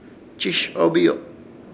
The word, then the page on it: Chishobiyo: Ishir Chamacoco